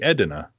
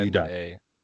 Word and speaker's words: Edina: So I'm like, okay, this must be Edina. Mm-hmm. No, that's me. – D-I-N-A. – Edina.